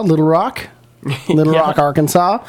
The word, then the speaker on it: Arkansas: But was it S-A-W, like saw? – Yeah, S-A-W. Yeah. It's pronounced Arkansas.